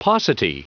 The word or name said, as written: paucity